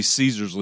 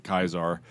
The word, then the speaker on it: Caesar: It's supposed to be Caesar's Legion, but they pronounce it Kaisar.